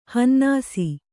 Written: ಹನ್ನಾಸಿ

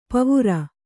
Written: ಪೀವರ

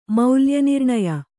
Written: ಮೌಲ್ಯನಿರ್ಣಯ

